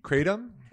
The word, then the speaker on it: kratom: Kratom? – K-R-A-T-O-M. – Pronounced kratom.